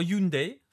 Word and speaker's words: Hyundai: il y, aura, il y a ensuite un, article sur Hyundai, T'as vu, je l'ai bien prononcé, Baptiste. J'ai bien fait attention. – Parfait.